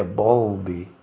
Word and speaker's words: Bowlby: A pronúncia fica difícil em português, ou mesmo lá para quem fala nossa língua, porque depois do W vem um L. Então seria Bowlby. Tem que fazer a língua ficar na diagonal para pronunciar corretamente.